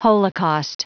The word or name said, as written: holocaust